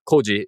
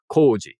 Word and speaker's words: koji: That one also has kind of a long O in case for anybody who's keeping score at home. So it's not koji, it's koji.